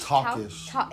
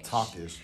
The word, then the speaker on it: Talkish: Talkish. Talkish, just how it's spelled.